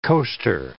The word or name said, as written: coaster